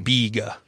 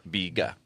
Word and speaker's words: biga: Biga. – Biga.